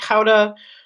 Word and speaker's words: Gouda: They have Edam, you have Gouda, or as they pronounce it, Gouda.